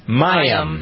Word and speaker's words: Mayim: Mayim.